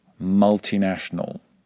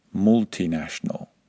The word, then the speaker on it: multinational: And it's exactly the same with multinational, not multinational. In fact, any word that begins with multi should be pronounced with a short vowel sound if you're following these rules.